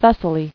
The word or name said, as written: Thessalian